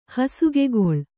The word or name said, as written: ಹಾಸುಗೆಗಲ್ಲು